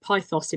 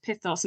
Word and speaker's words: pithos: So when you're reading it, in my head, certainly, I was calling it pythos in my head for a very long time, but that is actually pronounced pithos.